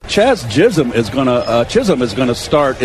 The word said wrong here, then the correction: Jazz: Add him to the long list, Jerry, of people who can pronounce Jazz. – Chaz Chisholm. – No, Jazz Chisholm.